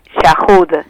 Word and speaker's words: Chajud: Yahud. – Yahud. – Sí, lo dije pero bien, bueno, entonces. Pronunciarlo, digamos que actualmente yo sigo aprendiendo cómo se pronuncia mi apellido.